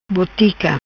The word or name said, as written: Botica